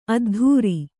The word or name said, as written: ಅದ್ಧೂರಿ